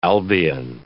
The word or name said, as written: Albion